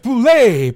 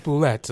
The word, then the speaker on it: bulette: Is that it's pronounced boulet, by the way, not boulette, everyone else says. – They said boulette at the Adventurers League.